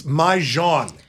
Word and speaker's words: Mahjong: It's Mahjong. Can we please pronounce it correctly? – Okay.